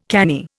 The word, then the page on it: canny: Indian